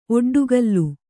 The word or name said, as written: ಒಡ್ಡುಗಲ್ಲು